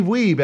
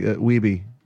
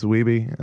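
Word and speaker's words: Wiebe: There's even a scene where, like, you know, fucking Walter Day is in King of Kong is going, Steve Weeb, everybody. He's like, Weeby. It's Weeby. And then, like, they're like, oh, we've been saying it wrong the whole time.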